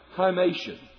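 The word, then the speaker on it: chymation (ἱμάτιον): Now, the interesting thing is the word for garment is the word, you and I would pronounce it, chymation.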